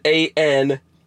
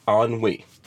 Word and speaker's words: ennui: You have to pronounce it ANWE, ennui.